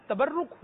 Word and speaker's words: تبرک: تو یہ تبرک حسین, درست لفظ جو ہے وہ ہے تبرک حسین. تبرک, ٹھیک ہے, برکت لینا اس کا یہ آتا ہے معنی. تبرک حسین, تبرک, تبرک حسین, یہ درست تلفظ نہیں ہے, تبرک حسین.